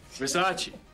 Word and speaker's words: Versace: It's a Versace. – It's Versace. – What? – Versace. It's pronounced Versace.